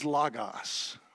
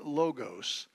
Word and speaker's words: logos: Now, the word that Socrates and Plato used to describe this ideal form from which all reproductions were made, that word is logos, or you may pronounce it logos, and it's translated Word.